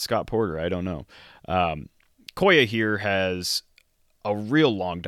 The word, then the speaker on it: Koya: Apparently it's pronounced Koya, because it's short for the real name, Sequoia.